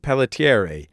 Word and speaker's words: Pelletieri: Let me tell you first that you got everything right, from Yay. – Italian history to the pronunciation of the name Pelletieri.